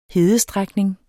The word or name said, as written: hedestrækning